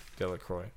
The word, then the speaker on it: Delacroix: Delacroix. Okay, there's the X. I'm sure it's freaking. The X is silent, so. Delacroix.